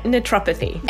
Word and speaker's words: naturopathy: huge transition from radiation therapy to naturopathy? Is that how I say it? I always say it wrong. – Yeah, perfect. Yeah, naturopathy.